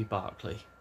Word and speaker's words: Berkeley: I think that should be Berkeley. I'm not sure. I know it's spelled Berkeley, but I'm fairly sure it's Berkeley.